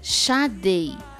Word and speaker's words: Sade: Gente, o certo é Sade.